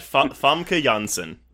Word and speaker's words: Famke Janssen: Famke Janssen.